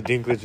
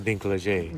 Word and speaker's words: Dinklage: You see, Dinklage is actually pronounced Dinklage.